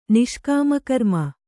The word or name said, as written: ನಿಷ್ಕಾಮಕರ್ಮ